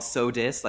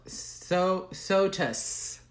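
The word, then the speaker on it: Sotus: It's called So Dis, like So Dis is Christmas. – No, So Tus. – So Dis, like, oh, rhymes with Christina Aguilera's best album. – Oh, actually, that's another trope that I want to bring up. So, yeah, like uh-huh, Lotus.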